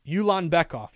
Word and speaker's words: Ulanbekov: Tagir Ulanbekov. Ulanbekov. U-L-A-N-B-E-K-O-V. Tagir.